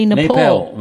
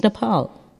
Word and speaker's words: Nepal: Same with Nepal. People, you say Nepal. – Nepal, when it's Nepal.